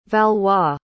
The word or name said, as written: valois